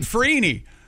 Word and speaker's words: Freeney: Dwight Freeney. – former Indianapolis Colt, is going into the Hall of Fame. – His last name rhymes with Weenie.